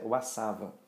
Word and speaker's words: WSAVA: Mas nós do mercado veterinário, do mercado pet principalmente, a nossa pronúncia é WASAVA, que também é o Vaccination Guidelines Group.